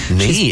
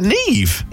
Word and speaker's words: Niamh: Shout out to Sarah, who named her daughter Niamh with the Irish spelling. – Niamh? Is that Niamh? – Yeah. – N-I-A-M-H? – Yeah, pronounce Niamh.